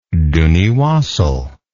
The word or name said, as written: duniewassal